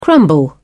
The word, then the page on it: crumble: British English